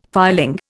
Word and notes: filing: Indian

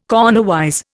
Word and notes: cornerwise: Indian

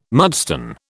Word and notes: mudstone: British English

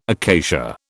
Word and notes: acacia: British English

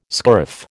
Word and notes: scurf: American English

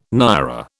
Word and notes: naira: British English

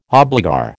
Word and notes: obligor: American English

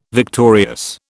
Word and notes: victorious: British English

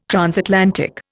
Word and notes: transatlantic: Indian

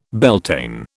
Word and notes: Beltane: British English

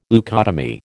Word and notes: leucotomy: American English

